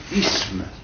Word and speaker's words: isme: Mais la prononciation correcte, c'est isme.